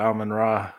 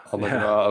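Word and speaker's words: Amon-Ra: The sun god, Amon Ra. – Amon Ra, okay.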